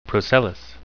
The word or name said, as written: procellous